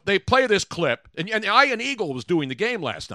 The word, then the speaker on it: Ian Eagle: No, it's Ian Eagle. I've known Ian Eagle forever.